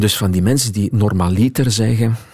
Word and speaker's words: normaliter: Maar je ziet daar weer die druk van die voorlaatste lettergreep die wij zo vaak beklemtonen in het Nederlands. En dus wordt dat ineens niet meer dat in ons gevoel vreemde, hè, normaliter.